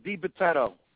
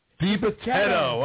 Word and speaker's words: Dibitetto: Vic Dibitetto. Is that how you say it, my man? – Nobody says it right. Dibitetto. – Dibitetto, all right.